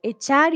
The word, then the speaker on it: echar: So remember, we don't pronounce the H in Spanish. So echar y echar with H or without the H sound the same.